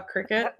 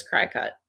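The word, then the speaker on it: Cricut: It's just how because it's not spelled like a cricket. Like, right. – So when I see it in my head, it's cry cut.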